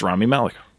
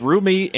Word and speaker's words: Rami Malek: It's Rami Malek. – What, how did you pronounce it? – I tried two different ones, and both were wrong. I said, like, Rumi and Raimi.